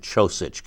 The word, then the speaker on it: Čosić: So every time I looked at the scorecard in front of me, I saw C-H-O-S-I-T-C-H. Yeah. And Čosić came from my mouth to make sure I was pronouncing it correctly.